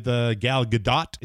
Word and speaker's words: Gadot: With that said, you know, you bring a new face on with Gal Gadot, is it? The T is pronounced.